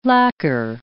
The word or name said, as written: lacquer